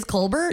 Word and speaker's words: Colbert: Is it the real pronunciation is Colbert? – Yeah.